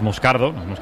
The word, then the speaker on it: Moscardo: Entonces, sí, como, es, como bien ha pronunciado Unai, es Moscardo, es brasileño.